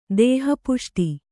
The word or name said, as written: ದೇಹಪುಷ್ಟಿ